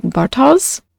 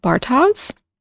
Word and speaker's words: Bartosz: Now, the character whose name I can't pronounce, I believe it's pronounced something like Bartosz, but I'm struggling. So we're going with Bartosz?